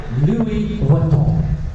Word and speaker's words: Louis Vuitton: Louis Vuitton.